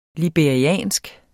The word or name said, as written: liberisk